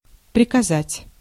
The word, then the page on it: приказать: /prʲɪkɐˈzatʲ/